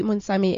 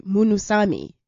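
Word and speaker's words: Munsamy: And then we're having an interesting conversation outside, because the way that your surname is spelt is very misleading, because it's spelled M-U-N-U-S-U-S-A-S-A-M-Y. – Mm-hmm. – And it's confusing, because when you read it, you want to say Munusami, which is not correct.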